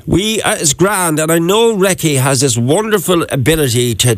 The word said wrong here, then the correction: reiki: So it's actually pronounced reiki, and it is a non-invasive hands-on therapy.